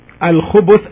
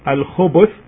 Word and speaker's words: الخبث: الخبث, as it's pronounced now by me, الخبث with the ba, with the letter ba taking a dhamma, as you can hear me, الخبث.